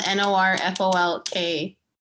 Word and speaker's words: Norfolk: That's so funny because um, my friends from Norfolk, it's spelled N O R F O L K, you know, Virginia, but they call it Norfolk. – Norfolk. – Norfolk. – Norfolk. – it's weird, right? But it's spelled Norfolk. folk.